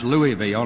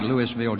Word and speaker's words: Louisville: and that's pronounced Louisville, not like Louisville, Georgia. Louisville, Kentucky, to be with us today, and they've been with us for quite a bit of the meeting.